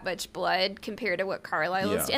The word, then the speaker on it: Carlisle: Carlisle.